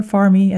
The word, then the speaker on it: Farm: We went to my favorite store, the farm store, Farmy, as you pronounce it in Portuguese.